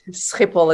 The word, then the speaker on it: Schiphol: Schiphol is how you pronounce it.